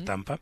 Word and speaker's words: Danba: se escribe Damba, pero se pronuncia Tampa,